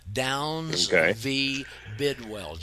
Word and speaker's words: Downs v. Bidwell: Downs v. Bidwell, just like it's spelled, it sounds.